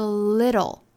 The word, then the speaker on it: little: 那后面的 little, little, 这个小音呢，里头的这个 TL 组合就不是舌边爆破的发音方法了，而是美音里头的独特的 flap T 的发音方法。它其实主要呢，难点就在于里面的这个字母 T, 里头要发 flap T, 就是弹舌音。